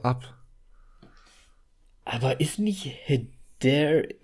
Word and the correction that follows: Hereditary: Ach Gott, ich kann das also nicht aussprechen. – Hereditary. – Hereditary.